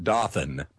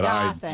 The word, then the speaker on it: Dothan: Yeah, she pronounces it Dothan, but Dothan.